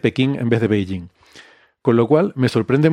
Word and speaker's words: Beijing: Cuando realmente la pronunciación se parece más a Beijing, pero bueno, era la forma en la que ellos lo escribían.